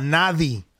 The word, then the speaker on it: nadie: A nadie. What's that? I don't say nadie. But it don't say yeah, right? N-A-D-I-E? – Nadie. – Oh, really? That's how you pronounce it?